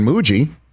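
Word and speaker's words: Mougey: And sure enough, the new general manager, Darren Mooji, rhymes with bougie, he signs them both to long-term deals in July of this past year.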